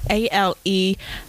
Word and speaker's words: Alemana: Okay, the next word is Alimana, which is Alemana.